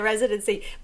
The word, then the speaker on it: residency: I didn't actually know that she was doing the Vegas residency ship. Residency ship? Where did I get residency ship? – Don't make it harder for yourself. – Just added on an extra syllable there. But the residency.